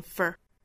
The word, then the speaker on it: for: 美音我们需要注意，首先第一个词 for 入读成 fur。 For the ideal that I hold, H-O-L-D.